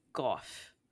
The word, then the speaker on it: Gauff: Gauff. I say her name Gauff because it's A-U.